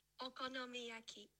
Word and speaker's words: okonomiyaki: Okonomiyaki.